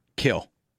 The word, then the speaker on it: Kiln: I don't know if he lives in Kill, Mississippi, or Hattiesburg, Mississippi. – I grew up in Kiln. – Kill. The M is. I think the M is silent.